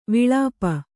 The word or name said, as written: ವಿಳಾಪ